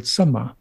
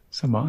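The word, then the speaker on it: sama: There's another word in Pali called samma, which is spelt with just one M, samma, and that means in tune.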